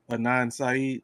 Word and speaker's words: Adnan Syed: Adnan Syed. – Adnan Syed.